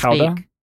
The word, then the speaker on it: Gouda: No, I think Gouda is from the Netherlands, I believe. And it's pronounced Gouda.